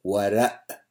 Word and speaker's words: Warraq: So Ibn Warraq, I mean, in my Arabic, we wouldn't say the k at the end. We'd say Warraq, but it's just a different pronunciation.